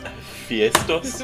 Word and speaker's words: Hephaestus: Actually, it's pronounced Hephaestus.